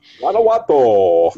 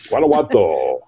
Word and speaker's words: Guanajuato: I don't know if I pronounced that right. – Guanajuato. – G U A N A J U A T O. – Guanajuato.